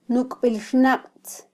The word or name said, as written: nukbilxnaqtz